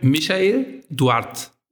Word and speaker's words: Michael Duarte: Also mein Name ist dann ganz korrekt Michael Duarte. Also ohne das E am Ende und das Michael ist halt klassisch deutsch.